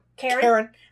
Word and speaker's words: Charon: CH is K. – Okay. Versus Charon, or Charon, however you pronounce it. I always read it. – CH would say K. – I'm talking about CHA. – It would be K. Okay. Still Charon. Charon.